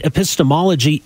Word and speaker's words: epistemology: And I'm really impressed that you pronounced epistemology correct. It's a kind of a difficult word for a lot of people.